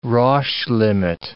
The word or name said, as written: Roche limit